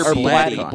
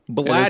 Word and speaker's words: Blatty: It's William Peter Blatty. – Blatty, that's what, yeah. – Blatty. – It is Blatty.